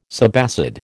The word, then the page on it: subacid: American English